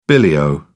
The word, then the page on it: billy o: British English